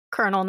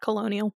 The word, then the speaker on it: colonel: It's Colonel. Colonel, not Colonial. – Is it Colonel? What? – Uh-huh. I know, it's spelled the stupidest way considering how it's pronounced. – There's no R.